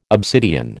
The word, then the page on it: obsidian: American English